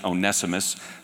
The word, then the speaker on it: Onesimus: That's how you pronounce it, Onesimus. Some of you may pronounce it Onesimus. If you do, then you're probably from England.